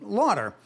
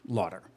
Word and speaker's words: Laughter: And, yeah, so I went with a pronunciation, Lauder. And Peter Lauder sounded much better than Peter Fuckup. And so life went on, and then I got married. And my wife, who took on my name, thought that I was completely psychotic for using the pronunciation Lauder when it was spelled Laughter.